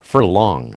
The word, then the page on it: furlong: American English